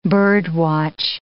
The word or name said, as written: bird-watch